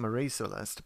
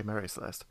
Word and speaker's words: Mary Celeste: And also, she's cleverer than a lot of English people at the time who are pronouncing Marie Celeste, but it's more proper to say Mary Celeste.